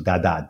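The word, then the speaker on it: Dadá: And she was transculturated with the Virgin of the Rosary, Dada, D-A-D-A, with an accent on the A.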